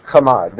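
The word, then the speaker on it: chamad (חמד): Chamad.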